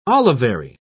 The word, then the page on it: olivary: British English